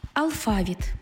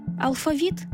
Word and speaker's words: алфавіт: Але перевагу слід віддавати тим, в яких, як свідчить історія мови, колись був один наголос. Наприклад, Алфавіт. – Алфавітний. Весняний. Жало. Завжди. Закінчити. Набережна. Простий. Також. Ясний. Тощо. Адже потім їх наблизили до російських.